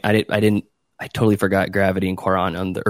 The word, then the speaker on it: Cuarón: Cuarón, I would say, yeah. – Cuarón.